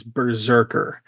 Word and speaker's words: BRZRKR: So my number five is Berserker. That is spelled B-R-Z-R-K-R, which is currently just, quote, expected in 2024, as are two others on my list. So Berserker,